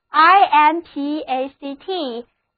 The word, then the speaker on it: impact: 最后我们来看这个单词 impact, impact, 重音放在第一个音节上， A 字母是 A 的梅花音，单词就读作 impact。